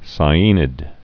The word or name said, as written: sciaenid